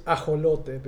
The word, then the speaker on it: ajolote: Yeah, and in Spanish, the word is ajolote because it's, with the X is pronounced as a J, so that's why we say Mexico instead of Mexico.